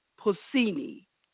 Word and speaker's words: Pausini: Her name is Laura Pausini, P-A-U-S-I-N-I, and yes, I pronounced it right.